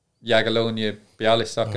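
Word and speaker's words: Jagiellonia Białystok: So I was in my last 6 months of my contract and Jagiellonia, Bialystok in Poland, they...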